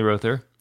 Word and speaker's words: Rother: Which is pronounced Rother, by the way. It's Rother.